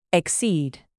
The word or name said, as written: exceed